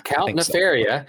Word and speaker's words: Count Nefaria: He he's a guy in a trench coat wearing a monocle, and his name is Count Nefaria. – Yeah. – Is that how you say it? – Yeah. – Count Nefaria.